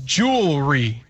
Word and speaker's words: jewelry: So we're going with just jewelry. Two syllables. – Jewelry. – Okay. – Like get that other syllable out here. – It's like Louisville.